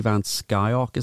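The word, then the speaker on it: Van Scyoc: So according to, and I have to pronounce this, I think it's Skyock, Gary Van Skyock is how you pronounce that.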